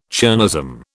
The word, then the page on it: chernozem: British English